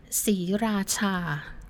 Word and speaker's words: Sriracha: Maybe we should start by how do you pronounce it? – Okay, Siracha. – The name Sira- is Siracha. Siracha.